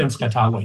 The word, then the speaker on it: Tenskwatawa: You have an excerpt from a speech of, and I'm going to mispronounce it, Tenskwatawa. Is that close? – From Tenskwatawa, yes.